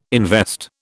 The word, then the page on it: invest: British English